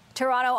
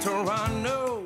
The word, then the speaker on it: Toronto: May we suggest Toronto? Just don't pronounce the second T.